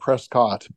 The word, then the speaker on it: Prescott: P-R-E-S-C-O-T-T, spelled Prescott, but it's pronounced Prescott.